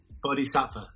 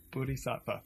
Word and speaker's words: Bodhisattva: I kind of want to go back to this subject, though, this idea of the warrior monk and this idea of, I want you to tell me how to pronounce this correctly, this Buddhist concept of helping everybody reach enlightenment. Buddhin? – Yeah. Bodhisattva. – Bodhisattva. Okay. Bodhisattva.